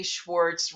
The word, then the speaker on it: Schwartz: So this kid, whose name is Valerie Schwartz, rhymes with warts, she's a nerd and she's a dork and she's not learning as much horsemanship as she wants to learn.